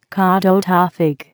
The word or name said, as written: Kadota fig